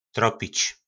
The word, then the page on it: tropic: po nasimu